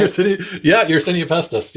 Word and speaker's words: Yersinia pestis: Yersinia pestis. Did I pronounce it right? – Yersinia pestis. Yeah.